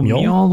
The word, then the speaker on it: Mjoll: They have listed, and again, pronunciations, Mjoll, M-J-O-L-L, the lioness. – Oh, Mjoll the lioness.